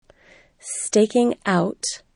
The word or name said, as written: staking out